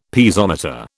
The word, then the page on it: Piezometer: British English